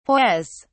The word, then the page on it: hoise: British English